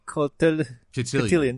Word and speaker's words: cotillion: Oh, how do you say that word? – Cotillion.